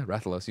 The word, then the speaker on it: Rathalos: Is that Rathalos? – That might have been a Rathalos, yes. – Is that how you say it? Is that the name? – Yeah, Rathalos. You nailed it.